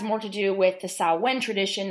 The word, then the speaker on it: Samhain: And it's written Samhain, pronounced completely differently as Samhain.